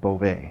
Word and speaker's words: Bove: Like in Italy, my name can be spelled B-O-V-E or B-O-V-E with an accent over the E, and you pronounce it like Bove. And two of my boys have taken that up. They like Bove better. So I go with Bove.